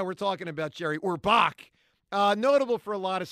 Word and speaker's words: Orbach: John, he passed away a few years back, but Jerry Orbach was, how do you say his name? Orbach? – Jerry Orbach. – Orbach. Yeah. Not Orville Redenbacher guy.